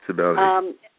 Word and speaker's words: Cybele: Well, and you know, what I'm wondering, you know, you hear about the crazy rites of, I don't know if you pronounce it Cybele or Cybele or Cybele. – Yeah, Cybele.